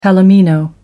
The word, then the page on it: palomino: American English